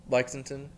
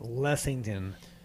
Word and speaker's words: Lexington: Lexington, Louisville. – I would say they're pronouncing it Lessington, not as good as, you know.